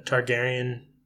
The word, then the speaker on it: Targaryen: I guess it just follows the Targaryen house.